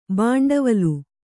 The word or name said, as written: ಭಿಂಡಿವಾಲ